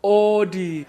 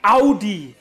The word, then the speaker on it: Audi: So, the first wrong car name, so many people say Audi, when it's actually pronounced Audi. Very easy.